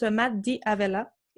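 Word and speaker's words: D'Avella: Euh, il s'appelle Matt Davella, puis il se prononce Matt Di Avella.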